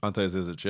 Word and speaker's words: Ante Zizic: Kyrie Irving to the Boston Celtics for Isaiah Thomas, Jay Crowder, Ante Zizic. – Ante Zizic, yep. – I say that right? – Yeah.